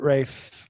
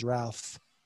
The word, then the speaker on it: Ralph: Well, we called him, we pronounced it Rafe because he's German, but you know, some might see him as Ralph.